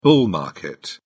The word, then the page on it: bull market: British English